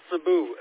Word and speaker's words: Sebuh: My name is Cebu. It's spelled S-E-B-U-H. My call sign is K-N-6-G-A-Z.